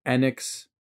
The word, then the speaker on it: Enix: It was made by one of the people who worked for Enix.